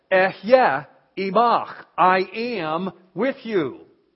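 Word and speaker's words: Eh, yeah (אהיה): Everybody say, just two syllables, everybody say, "Eh, yeah." "Eh, yeah" means I am. "Eh, yeah, asher, eh, yeah."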